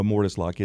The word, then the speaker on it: mortise: And what in the world is mortise door locks? What is am I pronounced that right? Mortise? – A mortise lock.